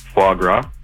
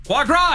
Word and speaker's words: foie gras: Foie gras. – Foie gras, he got it and pronounced it right.